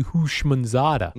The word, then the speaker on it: Houshmandzadeh: Houshmandzadeh.